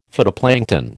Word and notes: phytoplankton: American English

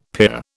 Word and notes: pier: British English

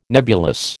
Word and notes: nebulous: American English

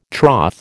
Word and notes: troth: American English

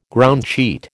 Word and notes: groundsheet: American English

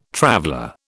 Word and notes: traveller: British English

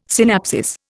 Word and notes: synapsis: Indian

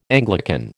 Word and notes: Anglican: American English

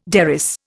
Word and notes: derris: Indian

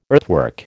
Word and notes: earthwork: American English

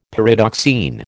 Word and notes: Pyridoxine: American English

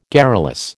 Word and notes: garrulous: American English

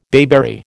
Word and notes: bayberry: American English

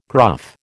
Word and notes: gruff: American English

